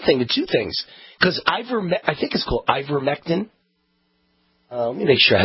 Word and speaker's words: ivermectin: Let me just make sure I'm pronouncing it right. Here we go. Okay, so it is ivermectin. Yeah, ivermectin. And it's on the approved list by the World Health Organization.